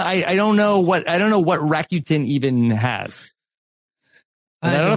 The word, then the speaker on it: Rakuten: So how do you pronounce that? – I think you got it right. Rakuten.